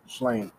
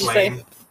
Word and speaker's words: Slane: Slane. – Slana? – Slana? – I don't know how to pronounce it. Slane. – S-L-A-N-E.